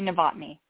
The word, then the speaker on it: Novotny: It's Amy Novotny. – Novotny. Oh, just like it appears on my piece of paper, Novotny.